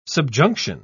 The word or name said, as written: subjunction